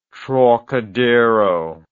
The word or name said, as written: Trocadero